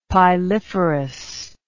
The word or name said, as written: piliferous